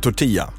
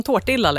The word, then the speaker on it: tortilla: Okej, grejen är ju så här, jag älskar ju tortilla. – Ja. – Som jag äntligen har lärt mig uttala rätt. – Heja, det är alltså inte en tårtilla längre.